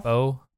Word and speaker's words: Bao: Bao? Boy? – Yeah. – B-A-O, I think it's pronounced Bao. Yeah, Bao.